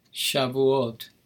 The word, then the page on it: Shavuot: Hebrew